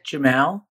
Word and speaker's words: Jemal: My name is Isaac Jemal, but I like people to call me Zuki.